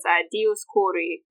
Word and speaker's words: Dioscuri: Actually, that name, the Dioscuri, that's the Latinization of it, but it's Dioscuri, if you're pronouncing it ancient instead of modern Greek.